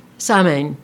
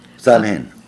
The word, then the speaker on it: Samhain: and they mentioned, and now I've forgotten how to, Samhain, but that's not how it's pronounced. – Samhain.